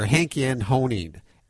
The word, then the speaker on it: Henkjan Honing: Dr. Henkjan Honing, and he's a researcher and he observes the way animals respond to music.